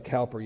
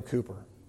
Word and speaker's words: Cowper: Looks like Cowper. You pronounce it Cooper, I'm told.